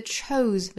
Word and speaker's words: chose: The two, 后面的 chose 尾部是 z 的发音，这个词我们要格外注意哈。虽然是 se，但是尾部并不是轻音的 s，而是它对应的浊音 z。